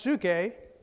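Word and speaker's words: suke (ψυχή): In the New Testament, it's pronounced suke,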